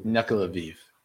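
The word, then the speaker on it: Nuckelavee: I'm trying to figure out how to pronounce this. – Which one? – Nuklaviv. – Nuklaviv? – Nuklaviv. I Google just told me how to say this.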